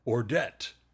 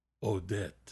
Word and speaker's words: Ordet: And I just looked it up on the internet and got the internet lady to pronounce it for me, and she says Ordet. So with no R sound.